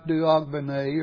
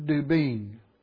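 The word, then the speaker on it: d'Aubigné: Merle Dubin, and the last name is spelled D with a slash, A-U-B-I-G-N-E, and it looks like Duogbene or something of that nature, but it's really pronounced Dubin. It's a French name.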